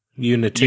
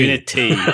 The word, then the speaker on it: Unity: Unit. – Unity. – Unity.